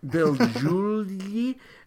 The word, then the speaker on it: Beljulji: While I think the played, second J is silent. – Bill Giulie.